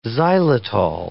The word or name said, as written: xylitol